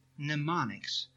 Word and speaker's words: mnemonics: It is mnemonics, but it is spelled M-N.